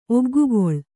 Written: ಒಗ್ಗುಗೊಳ್

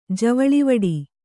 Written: ಜವಳಿವಿಡಿ

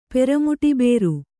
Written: ಪೆರಮುಟಿಬೇರು

